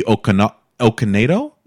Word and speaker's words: Okonedo: Sophie Okonedo. Did I do that right? Yeah.